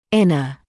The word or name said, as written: inner